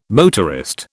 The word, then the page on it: motorist: British English